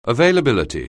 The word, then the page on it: availability: anglais